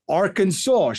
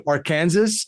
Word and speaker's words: Arkansas: Like Arkansas, shouldn't that be Arkansas if we have Kansas, right? – Fair enough.